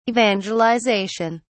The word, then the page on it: evangelisation: British English